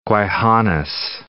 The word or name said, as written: Gwaii Haanas National Park Reserve